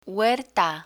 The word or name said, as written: huerta